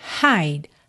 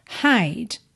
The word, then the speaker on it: hide: Aqui de novo, atenção, que essa letra E da palavra hide não é pronunciada. E a letra H em inglês tem esse som, hide, hide.